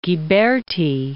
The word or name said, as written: Ghiberti